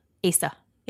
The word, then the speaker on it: Asa: How's the name spelled? – A-S-A. – Asa. – Asa. – Didn't you watch One Life to Live? Asa Buchanan. – Nope. – Okay. – She trampled 67-year-old Asa Sheetal.